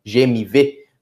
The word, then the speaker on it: GMV: Então, Via Varejo veio aí com mais um resultado forte em termos de receita, expansão de GMV, né, que é Gross Merchandise Value. Tá, a pronúncia tá certa aí? Tá.